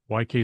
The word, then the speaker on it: Waikay: YK is the one we talk about a lot, just because the one I latched onto, I kind of like it. And that's spelled W-A-I-K-A-Y. Not what you expect, right? W-A-I-K-A-Y.